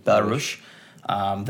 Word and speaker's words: Belarus: So Białoruś, I don't know if that's how you pronounce it in English, but in Polish it's Białoruś. I think Białoruś. It's Belarus.